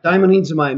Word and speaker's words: daimonizomai: And there's the Greek word daimonizomai. Did I say that right? – Yeah, daimonizomai means to be demonized.